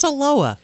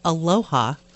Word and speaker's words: Aloha: For those of you not listening from the Portland area, it is spelled Aloha, but it is pronounced Aloha.